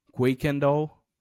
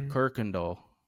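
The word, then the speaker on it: Kuykendahl: So if you look how it's spelled, you will say Quakendall, but it's actually Kirkendall.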